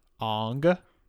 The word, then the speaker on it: Aang: Aang. We're going to talk about this movie. We're going to say it wrong. – Okay. When Aang goes to the air temple. – It sounds like Dong.